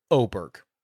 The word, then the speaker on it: Åberg: We had this discussion about how are Americans supposed to pronounce Ludvig's name? Is it Åberg? Is it Åberg? What is it? And he told me, based on like the English dialect, the acceptable English pronunciation would be Åberg. Like, oh my God, Berg.